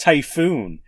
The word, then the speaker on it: Taifun: I'm just realizing right now, I think that's actually supposed to be pronounced Typhoon. It's spelt Taifun. That's what the fan is. But I think it's Typhoon.